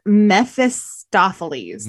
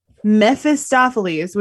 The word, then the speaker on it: Mephistopheles: And he goes, I will correct your pronunciation on that. – What is it? – It's Mephistopheles. – Mephistopheles? – Yeah. Mephistopheles, which is spelled like M-E-P-H-I-S-T-O.